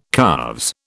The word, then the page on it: calves: British English